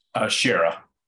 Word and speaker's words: Schara: Schara.